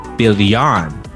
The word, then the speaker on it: Billian: So I've been informed I did butcher that name. It is Anna Billian.